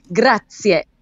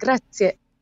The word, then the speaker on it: Grazie: The simple word that means thank you, it has a little bit of a vowel sound at the end that we Americani may not be used to. Grazie. It's got that little bit of an eh at the end. Grazie. Like that.